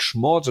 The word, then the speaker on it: Schmalz: Da sprechen wir sie aus, wie sie in den USA aussprechen. Nick Schmalz und Ryan Hartmann.